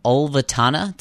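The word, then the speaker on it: Ulvetanna: and the South Ridge of Ultevanna. Did I pronounce that right, Andy? – Yeah, that's right, yeah.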